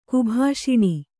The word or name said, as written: ಕುಭಾಷಿಣಿ